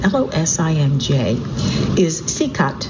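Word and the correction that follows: Čikat: Chikat. It's nice, he's giving me pronunciations as I go. So the jewel of Losange is Chikat, C-I-K-A-T, Bay,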